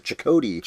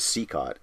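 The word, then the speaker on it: Cicotte: We learn in Field of Dreams, I think inaccurately, they pronounce it Chakotay. – Chakotay. – Which looks like it could be that too, but apparently it is Seacott.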